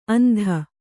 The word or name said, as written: ಅಂದು